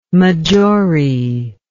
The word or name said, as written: maggiore